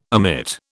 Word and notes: omit: British English

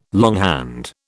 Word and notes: longhand: British English

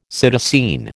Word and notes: psittacine: American English

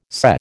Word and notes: SCE: American English

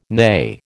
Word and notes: nay: American English

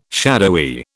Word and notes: shadowy: British English

